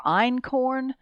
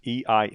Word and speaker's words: einkorn: I'm not saying they're the only ones, but the ones you hear about are einkorn. And that's einkorn. – A German word, right? Einkorn. And emmer, E-M-M-M-E-R, and then spelt, which is spelt, just like it sounds.